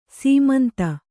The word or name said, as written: ಸಮಂತು